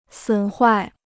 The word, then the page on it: 损坏: sǔn huài